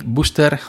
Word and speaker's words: Booster: Clark Technic Mic Booster o Booster con dos O, las dos O en inglés se pronuncia como una U.